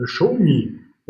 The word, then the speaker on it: Xiaomi: Bitcoin steht vor dem Allzeithoch, Anheuser-Busch ist ein Thema und Xiaomi, richtig ausgesprochen, am Automarkt.